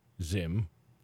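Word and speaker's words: Zzyym: Zim, pronounced Zim, because it's Z-Z-Y-Y-M. – A double Z. – Double Z, double Y-M.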